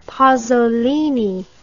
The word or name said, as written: pasolini